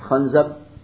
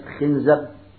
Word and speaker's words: خنزب: هذه الكلمة تنطق بلغات ثلاث، تنطق بالفتح خنزب، وبالكسر خنزب، وبالضم خنزب، واللغات الثلاث صحيحة وأشهرها الفتح خنزب.